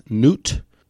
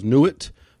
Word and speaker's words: Nut: The seventh plague attacked the sky goddess Nut. So it could also be pronounced Nuit,